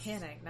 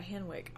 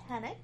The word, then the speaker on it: Henwick: I think I saw Jessica Hennick, which is, we have learned how you pronounce Hennick, not Henwick, all this time. – Yeah, the woman playing Colleen Wing. You pronounce her name Hennick.